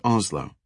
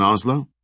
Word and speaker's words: OSLO: a compartment the crew referred to as Oslo. The acronym stood for Off-Ship and Land Operations. The crew pronounced it like the name of the Norwegian capital and referred to it that way, too.